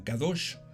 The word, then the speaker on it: kadosh: Y esto en hebreo podemos mencionarlo o podemos decirlo como kadosh.